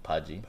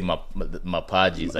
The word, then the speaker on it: Mpagi: And they people had a hard time saying my name, Paji. – Paji, yeah. – Because of how it's, like, spelled yeah. The, you know, M-P-A-G-I. So they would say Mapaji.